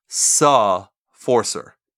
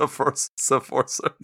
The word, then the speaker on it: Sefforcer: So they spell it SEFFORCER, and you pronounce it Suh Forcer, SUH, Suh Forcer. It's a French word. Don't hold it against them. But it's pronounced Sephorcer.